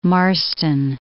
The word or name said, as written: Marston